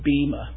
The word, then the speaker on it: bema: The usual English pronunciation is bema, B-E-M-A. But the Greek is literally bema.